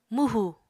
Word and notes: mwhù: Apatani

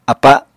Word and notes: apa: Apatani

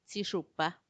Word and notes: tsixupa: Macuiltianguis

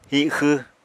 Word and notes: hixww: Apatani

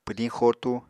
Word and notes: pwdiñ xotu: Apatani

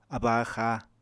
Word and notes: abba-axá: Apatani

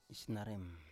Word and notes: isnarem: Biak

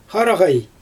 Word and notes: xaraɣaj: Xyzyl

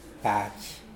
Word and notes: pahj: Mokilese

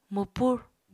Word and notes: mwpur: Apatani

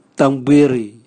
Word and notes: tangbwiri: Raga

